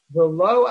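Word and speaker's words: V'lo (ולו): We need to take note here that the word V'lo is a ksiv and a kri, it's written Vav Lamed Aleph, but it's pronounced Vav, it's pronounced Vav Lamed Vav.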